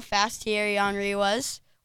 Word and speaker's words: Henry: Henry.